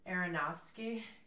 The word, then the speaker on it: Aronofsky: Aronofsky.